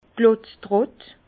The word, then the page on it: d' Klotztrott: Bas-Rhin; Herrlisheim